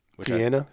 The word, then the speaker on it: Vienna: I grew up eating, I think I say this wrong. We always call them Vianney Weenies. – Vienna? – But we call them Vianney Weenies.